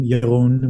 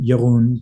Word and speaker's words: Jeroen: So my Instagram handle is my name, Jeroen, which is spelled Jeroen, and then is love, ISLOVE, or my website, but you'll find my website on my Instagram as well.